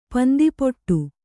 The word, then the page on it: ಪಂದಿಪುಟ್ಟು: pandipuṭṭu